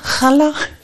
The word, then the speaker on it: Challa: Und dann habe ich den halt drauf gezeigt und dann leuchteten ihre Augen und dann sagte sie, ah, Challa. Also es wird anders ausgesprochen, als ich dachte.